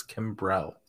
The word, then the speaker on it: Kimbrel: Yeah, I think my favorite guy in this list is probably Kimbrell, because he has – I always say Kimbrell. There's not two R's in his name. It's Kimbrell. I don't know why I can't say his name right, but I haven't been able to do it for like 5 years.